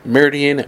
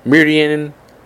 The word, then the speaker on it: Meridian: Next, Chase Purdy, the 18-year-old from Meridian, Mississippi. Excuse me, butchered his hometown there. Meridian, Mississippi.